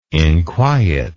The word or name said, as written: inquiet